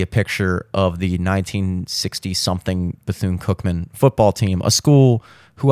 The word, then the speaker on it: Bethune-Cookman: And it looks like they want you to pronounce it Bethune, Bethune-Cookman, Bethune, which really just sounds like you're a dude with a lisp trying to say bassoon.